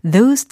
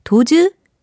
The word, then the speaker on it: those: those things 라고 하셔야지 o 도즈, things 이렇게 하시면 안 되겠습니다.